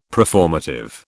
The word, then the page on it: preformative: British English